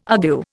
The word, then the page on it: argue: Indian